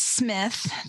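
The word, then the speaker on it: Psmith: My favorite, though, is Smith. The P is silent.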